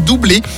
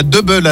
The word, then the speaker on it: Double: En 1983, à deux, ils deviennent doublés. Il faut bien le prononcer doublé à la française et pas double à l'anglaise.